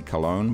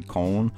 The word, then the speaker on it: Köln: Most people say Cologne, but the Germans say Köln, and he actually refers to saying it wrong in the song.